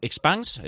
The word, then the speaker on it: Expanse: Bueno, yo aquí todo lo que veo son ventajas, pero por ejemplo vosotros desde Virtual, a ver si lo pronuncio bien, Expanse, sí. Expanse, ¿cómo lo pronuncia su responsable? – Yo le digo expanse, ¿no? Que es como expansión en inglés, así un poco.